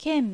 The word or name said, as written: Ken